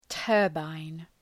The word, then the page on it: turbine: ‘tɜ:rbən